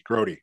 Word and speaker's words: Grote: So I don't know how to say this guy's last name. Mark Grote? – Mark Grody. Grody.